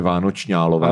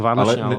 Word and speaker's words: Vánočniálové: Vánoční, vánoční, alové je tady i i, eh, je tady i napsáno, jak se to má vyslovovat, a to je Vánočňálové.